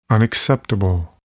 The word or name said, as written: unacceptable